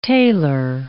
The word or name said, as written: tailor